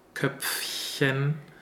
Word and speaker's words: Köpfchen: Don't be too lazy just to pronounce the F. We really need the p, p, Köpfchen. Also here is a small stop before the PF.